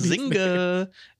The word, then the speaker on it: Zynga: Zynga.